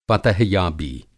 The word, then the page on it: फ़तहयाबी: فتح یابی